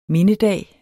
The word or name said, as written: mindedag